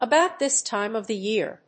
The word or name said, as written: about this time of the year